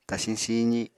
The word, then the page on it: tasiñ siizi: apatani